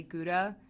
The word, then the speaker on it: Gouda: So I'm going to go with Gouda just because I'm not from there.